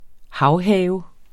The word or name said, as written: havhave